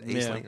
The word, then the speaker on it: Aisling: And it deals with this young woman named Claire, played by Aisling, I think is how you pronounce it. Aisling, yeah.